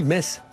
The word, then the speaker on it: Metz: Voilà, il y a eu des problèmes parce qu'on dit Metz tout bêtement, même si ça s'écrit M E T Z.